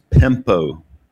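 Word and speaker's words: pempo (πέμπω): Okay, so the first Greek word is pempo. We would spell it P-E-M-P-O, pempo.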